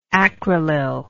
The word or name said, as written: acrylyl